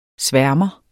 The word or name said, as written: sværmer